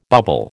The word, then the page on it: bubble: American English